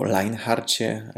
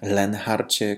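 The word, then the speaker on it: Leonhard: Drodzy, pozwólcie, że dzisiaj przypomnę o pewnej postaci, o Leonharcie Kaiserze, albo Leinharcie, Lenharcie Kaiserze. Różnie wymawia się jego imię i nazwisko.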